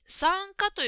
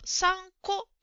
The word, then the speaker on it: 参加: それから、質問と答えの文に参加という言葉があるのですが、ジムさんはこれを参加と発音していました。正しくは参加ですね。